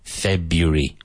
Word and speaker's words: February: Now, you can have a few different pronunciations of this in English, but none of them are February. So I say February. Three syllables.